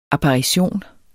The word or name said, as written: apparition